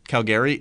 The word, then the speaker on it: Calgary: I'm also trying to think, is Calgary known for oil? – It's pronounced Calgary. – Calgary? Are they known for oil or no? – It's Calgary. – It's Calgary. Get it right.